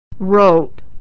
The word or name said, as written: rote